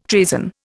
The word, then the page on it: treason: Indian